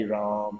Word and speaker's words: Rama: The at the end of Rama is very, very soft. It's Ram. Ram is the same. Okay. When Prabhupada would chant, he'd go, Hari Ram, Hari Ram, Ram, Ram, Hari Hari.